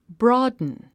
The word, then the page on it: broaden: brɔ’ːdn; ブロードゥン